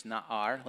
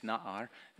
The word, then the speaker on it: na'ar: The word is na'ar. Let's say na'ar. I think it's na'ar. The emphasis on the first syllable.